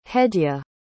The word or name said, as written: hedya